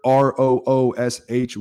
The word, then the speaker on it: Roosh: Uh, Twitter, R O O S H Williams. That is R O O S H Roosh Williams, rhymes with swoosh.